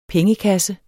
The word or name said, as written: pengekasse